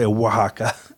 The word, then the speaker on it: Oaxaca: We can just say Oaxaca. – Okay. – I was just trying to phonetically, like, sound it out.